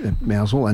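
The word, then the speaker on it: Mousehole: Through some of these towns, Mousel was another one I should mention. It's spelt Mouse Hole, it's Mousel and Penzance.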